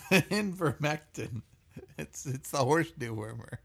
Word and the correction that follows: ivermectin: I would never be anti horse dewormer because it's ivermectin.